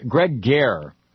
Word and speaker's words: Garre: Greg Gehr, I believe that's how it's pronounced, G-A-R-R-E, Gregory Gehr is the Deputy Solicitor General.